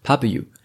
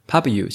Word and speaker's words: Pubu: 在分享今天的书之前呢，来介绍一下本集节目的赞助厂商 Pubu。